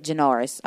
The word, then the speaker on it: Janoris: Oh, that's how you say it. Janoris.